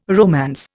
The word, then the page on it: romance: Indian